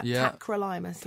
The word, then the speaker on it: Tacrolimus: Tacloramus. – Right. – Tacrolimus, is I'm saying that right? – Yeah. Tacrolimus, yeah.